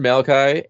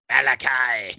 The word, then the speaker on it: Malachi: So we have Children of the Corn, Malachi, and Job. That begins shipping. – You didn't say it right. – Malachi.